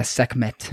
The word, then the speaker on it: Sekhmet: It's like Sekhmet. – Okay. – Yeah, Sekhmet.